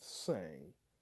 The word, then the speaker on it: Tsang: Funny Kung Fu, directed by Kenneth Tsang. It's T-S-A-N-G. The T is silent.